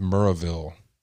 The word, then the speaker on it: Maryville: I used to live in a town in Tennessee called, it was spelled Maryville, but it was pronounced Muraville.